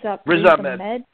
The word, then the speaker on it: Ahmed: Ahmed? – Riz Ahmed.